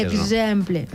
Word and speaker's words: exemple: La meva parla tampoc, és exemple. – Ni exemple, ni exemple. – Exacte, exemple. – No? Exemple. – Que vibren les cordes vocals.